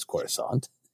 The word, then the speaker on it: Coruscant: This is the sketch where I mispronounced Coruscant as Coruscant because I hadn't actually heard it spoken. Right. And then my friend was like, I've actually talked to Timothy Zahn and it is Coruscant.